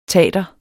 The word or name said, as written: tater